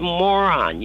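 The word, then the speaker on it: Moran: And he said, 'It's pronounced moron. You're a moron.'"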